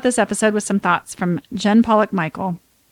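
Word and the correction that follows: Michel: No, Michelle. – I don't know how you say it.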